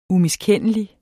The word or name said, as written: umiskendelig